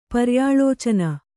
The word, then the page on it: ಪರ್ಯಾಲೋಚನೆ: paryālōcane